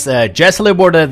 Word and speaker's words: Jesse LeBourdais: That was Jesse LeBourdais. This is with his brand. wow, that was not very good pronunciation. Anyways, Jesse LeBourdais, that his brand new song called Burnt Out Frame, which will be out on the split 7-inch of John Green called Home.